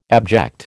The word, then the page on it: abject: American English